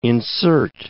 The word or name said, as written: insert